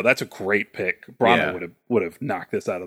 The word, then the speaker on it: Branagh: Kenneth Branagh. Is that how you say his name? – Branagh.